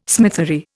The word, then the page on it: smithery: Indian